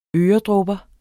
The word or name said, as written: øredråber